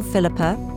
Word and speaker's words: Philippa: And yes, there are a number of ways to spell Philippa, and my last name is Swedish, so let me spell that for you.